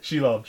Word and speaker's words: Shelob: How do you pronounce it? – Shelob. – Shelob.